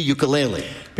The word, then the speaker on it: ukulele: the ukulele.